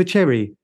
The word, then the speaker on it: Cherry: Ava Sherry. – Oh, Ava Cherry. – Ava Cherry. Yeah, sorry for my pronunciation, you know.